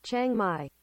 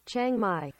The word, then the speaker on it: Chiang Mai: I'm going to say it's Chiang Mai. What do you say, trusty translator? Chiang Mai. What is it again? Chiang Mai.